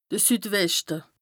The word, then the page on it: de Süd-Weschte: Bas Rhin; Strasbourg